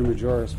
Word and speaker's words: Majoris: It looks like a J. Is that a silent J? – No, it's pronounced, Mr. Chairman, it's Walter Majoris for the record,